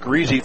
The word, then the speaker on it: Grisez: It was Caden Greasy, spelled G-R-I-S-E-Z, pronounced Greasy.